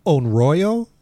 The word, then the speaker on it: Onryo: I believe it's pronounced Onryo. Onryo? It's O-N-R-Y-O.